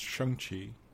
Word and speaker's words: Shang-Chi: And I think with, and I know it's kind of a month before, it's actually pronounced Shang-Chi. I was corrected.